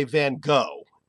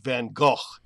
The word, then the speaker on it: Van Gogh: And the interesting thing was Americans say Van Gogh and mispronounce his name. Brits use the G-H with the F sound. They say Van Gogh. And we found a BBC pronunciation unit trying to tell British broadcasters how to correctly pronounce the name of the artist. And she advocated, of course, as did Kathy, something like a modified Dutch Van Gogh.